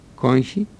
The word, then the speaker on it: κόγχη: Λεγόταν κόγχη, με γάμαχη, κόγχη, στην αρχαία Ελλάδα.